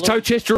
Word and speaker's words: Towcester: A Towcester? – Yeah, six dog fields over there. – Is Towcester all right? Am I pronouncing that okay? – Yep, yep.